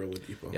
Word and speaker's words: Oladipo: Oladipo. – Are we saying that right? – Yeah, that's a basketball player. – Okay. – Well, his last name. – Victor. I actually didn't know that. – Victor Oladipo.